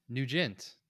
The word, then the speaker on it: Nugent: Nugent. – Nugent. – Yes. – How do you spell that? – N-U-G-E-N-T.